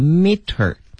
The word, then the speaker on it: metre: 这个后缀啊，大家看有点长，但是呢，当我们读一读，您就发现，这个发音呢，就有点像 meter，它主要是米的意思。